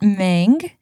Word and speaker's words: Meng: They're greeted inside by Aunt Wu's assistant, Meng, who is very excited that her name rhymes with Aang, as she should be.